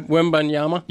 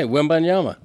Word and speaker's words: Wembanyama: So you want to try Wembanyama? – Yeah, he's right. – What did I say? Wembanyama. – Wemby. – You first time you kind of butchered it.